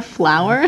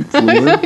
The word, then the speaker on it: Fleur: you call her Flower or something? – No, I call her Fleur. – That's what it is.